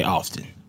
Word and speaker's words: often: and he said that he drives him crazy when people hit the T in often because, you know, they think they're sounding smart, but that's actually not how it's pronounced.